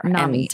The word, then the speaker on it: NAMI: Is it pronounced NAMI? Is it NAMI? NAMI. – NAMI. Yes. Thank you.